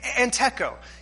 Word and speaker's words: entecho: Entecho. It's a Greek word, and it's simply, you pronounce it entecho.